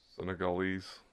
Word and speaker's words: Senegalese: The movie follows an 11-year-old Senegalese, did I say that right, girl? – Senegalese.